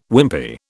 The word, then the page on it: Wimpy: British English